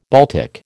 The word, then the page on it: Baltic: American English